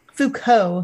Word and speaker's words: Foucault: Other sources in the over 1,600 pages of source documents include Foucault. I don't know if I'm saying that right. – Foucault, sorry.